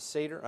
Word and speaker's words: Seder: And the Passover meal at the time of Jesus is called Seder, S-E-D-E-R. Is it pronounced Seder? Is it Seder? I think it's Seder, rhymes with Vader, not Cedar.